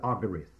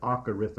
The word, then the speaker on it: acharith: In the Hebrew, there is the word agarith. That is spelled A-C-H-A-R-I-T-H.